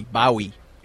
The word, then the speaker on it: Bowie: Not Bowie. Bowie is how he pronounces his name.